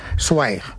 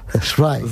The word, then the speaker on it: Zweig: Por el camino bien, ahora hay que decir Zweig. – Zweig. – Zweig. Esteban Zweig. – Es así como se pronuncia en alemán.